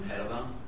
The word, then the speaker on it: Teitelbaum: Teitelbaum.